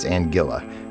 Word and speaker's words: Anguilla: She said it's Anguilla. She says it rhymes with vanilla, so I'm assuming it's Anguilla. It's A-N-G-U-I-L-L-A.